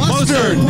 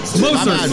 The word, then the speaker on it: Mostert: What's his name? – Mostert. – Mustard. No, not like Honey Dijon mustard. – Yeah, that's what I thought it was. – No, you idiot. Mostert.